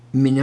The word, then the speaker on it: Meni: Now, the word number here is another word in the Hebrew that's pronounced Meni, I guess, M-E-N-I, which was another pagan god.